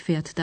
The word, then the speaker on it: fährt: Эти глаголы во втором и третьем лице единственного числа меняют корневую гласную «а» – на «а» умляут. По-русски она произносится как звук «э». Пример глаголов в третьем лице единственного числа. – Fahren. – Der Bus Nummer 40 fährt dahin.